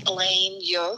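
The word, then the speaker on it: Elaine Yeoh: I have an Instagram called Elaine Yeo Photography. – Could you just spell that, please? – Yes. So it's Elaine, E-L-A-I-N-E.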